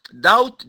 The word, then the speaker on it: doubt: فبقول أول حاجة شفتها in this book، يا ريت نحفظها، in this book there is no doubt، doubt D-O-U-B-T، البي هنا silent ميوت لا تنطق، in this book there is no doubt، ذلك الكتاب لا ريب فيه.